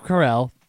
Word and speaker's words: Carell: Steve Carell. Did I say it right? – Yeah, you got it.